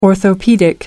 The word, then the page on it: orthopaedic: American English